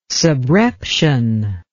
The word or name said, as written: subreption